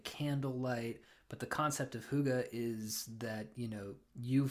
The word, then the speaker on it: Hygge: It's called The Little Book of Hygge, which is spelled Hygge.